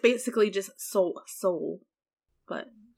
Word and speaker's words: Seoul: how do you say that? Seoul? The Korean city? – Seoul? – Is that what it is? – Seoul? – Yeah, Seoul.